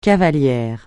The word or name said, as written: cavalière